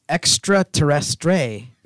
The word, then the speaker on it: extraterrestre: By the way, it's extraterrestre. That's how you say it. That's how you say it in French.